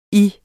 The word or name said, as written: Jer